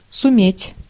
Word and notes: суметь: /sʊˈmʲetʲ/

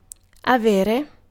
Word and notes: avere: [a.ˈve.re]

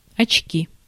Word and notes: очки: /ɐt͡ɕˈkʲi/

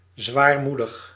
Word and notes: mélancolique: /me.lɑ̃.kɔ.lik/

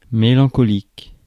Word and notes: mélancolique: /me.lɑ̃.kɔ.lik/

